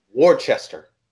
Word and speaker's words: Worcester: You don't know how many people say Worcester, and it's the most annoying thing. Because that's how it's spelled. It's W-O-R-C-E-S-T-E-R, which in all sense means Worcester. But it's Worcester. All right? It's Worcester.